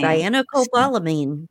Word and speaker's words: Cyanocobalamin: Cyanocobalamin.